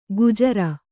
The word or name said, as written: ಗುಜರ